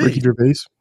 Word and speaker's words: Ricky Gervais: Richie Gervais. – Ricky Gervais.